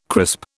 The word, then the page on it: crisp: British English